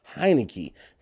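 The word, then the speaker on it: Heinicke: I'm sorry, that Heineke, that rhymes with Heineken.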